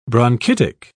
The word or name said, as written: bronchitic